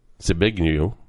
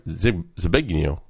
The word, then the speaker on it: Zbigniew: The salient part of this, Zbigniew. That's it. I said it right. Zbigniew.